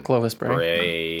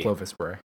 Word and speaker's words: Clovis Bray: Clovis Bray. – Clovis Bray.